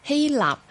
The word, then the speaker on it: 希臘: 譬如話，奧運會嘅發源地係希臘，但係好多人咧都將佢讀成希臘噶嚇。咁啊，而家都不時會聽到呢一個錯讀噶。咁明明嗰個希臘個臘字咧，我哋嘅寫法就係嗰個臘味飯啊、臘粥啊、臘腸嗰個臘字啦，冇理由咧就將佢讀成獵人嗰個獵字噶。